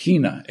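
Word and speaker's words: hina (ἵνα): The little word that they use is pronounced hina, H-I-N-A.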